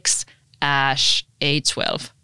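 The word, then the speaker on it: X Æ A-12: Ja meillä on yhteinen lapsi, jonka nimi on, se kirjoitetaan XAEA-12, ja se kuulemma lausutaan XASH-A12.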